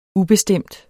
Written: ubestemt

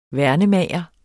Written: værnemager

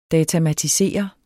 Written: datamatisere

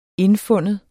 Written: indfinde

